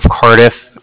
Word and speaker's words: Cardiff: Mm-hmm. – And I was calling with the Bank of Cardiff. And Cardiff is spelled C-A-R-D-I-F-F, as in Frank. It's like car and different.